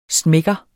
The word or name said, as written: smækker